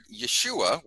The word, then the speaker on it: Yeshua: So the word Yeshua, which how it's pronounced in Hebrew, you can almost hear how it's similar to Yahweh, Yeshua, and it basically means God saves or Yahweh saves.